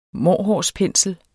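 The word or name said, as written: MÅRHUND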